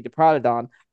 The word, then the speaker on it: Diprotodon: The most popular theory for it was naming the Diprotodon.